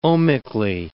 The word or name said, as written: ohmically